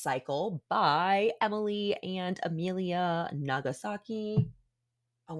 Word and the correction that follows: Nagoski: my God, I've been saying it wrong the whole time, you guys. It's Nagoski.